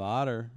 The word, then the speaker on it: OTA: And the Orthodontic Technicians Association, which is abbreviated as OTA, is apparently pronounced... – Otter. – Otter. That's how they pronounce it.